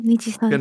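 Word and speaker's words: Nijisanji: Nija, Niji Sanji. Ja, genau, Niji Sanji.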